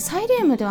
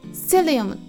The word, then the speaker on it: psyllium: みっていうとちょっと分かりづらくなってしまうかもしれないんですけれども、ま本格的に英語で言うとあの実はねサイリウムではなくてねセリウムっていうんですね A 文字の PSYLLIUM と書いてセリウムって読むんですけれどもこれなんか日本語だとサイリウムって読むみたいなんですね